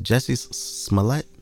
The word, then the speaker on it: Jussie Smollett: Is it Jussie Smollett? – Yeah.